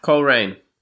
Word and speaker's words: Coleraine: Is it Coleraine in Ireland? – What? – Coleraine. Is that how you say it? Is that what the course is? Coleraine. – Coleraine. Yeah, it's like Coleraine.